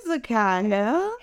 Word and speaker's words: Zakaiya: she hails from the East Coast DMV area, like I said before, and her name is Zakaiya Purnell. Everybody give it up for Miss Zakaiya. – Yep. – Hey, Zakaiya.